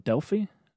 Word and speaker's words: Delphi: I think the most popular is the Oracle of Delphi.